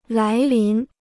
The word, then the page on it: 来临: lái lín